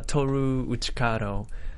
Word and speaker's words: Toru Uchikado: Toru Uchikado, if you can spell it.